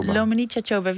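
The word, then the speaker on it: Luminița Cioabă: En jij noemde Dominique Chachoba, zeg ik dat goed? – Lumanica Choba, wie is dat? – Dat is een Romanese schrijfster uit Roemenië.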